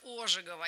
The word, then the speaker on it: Ожегова: Поздравили всех с днем рождения лингвиста Сергея Ивановича Ожегова. И наверняка запомнили, что именно так ставится ударение в его фамилии.